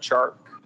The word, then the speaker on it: Chark: I played a Jaguars receiver, Mr. Shark, even though it's spelled C-H-A-R-K. Isn't it Shark, not Chark? – No, it's Chark. – It is Chark?